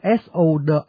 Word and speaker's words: sword: tuy viết sword nhưng mà đọc bỏ chữ w không đọc, đọc là sword.